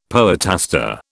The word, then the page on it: poetaster: British English